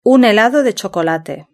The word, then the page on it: un helado de chocolate: European Spanish